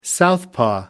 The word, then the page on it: southpaw: American English